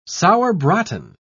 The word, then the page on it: sauerbraten: British English